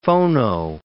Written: phono

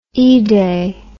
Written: eday

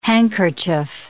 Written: handkerchief

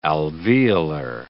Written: alveolar

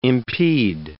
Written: impede

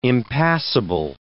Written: impassible

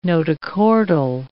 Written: notochordal

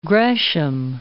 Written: Gresham

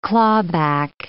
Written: clawback

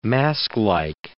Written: masklike

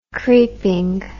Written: creepingly

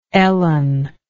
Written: Ellen

